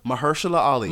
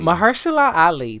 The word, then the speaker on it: Mahershala Ali: Mahershala Ali. – Mahershala Ali.